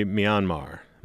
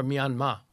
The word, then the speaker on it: Myanmar: Am I pronouncing that right? I don't think I am. – Pretty close. In the language of the country, they dropped the R at the end, so it's more Myanmar.